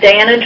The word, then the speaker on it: Danna: Okay, my name is Dana Droz. First name is spelled D-A-N-N-A. Last name is spelled D-R-O-Z.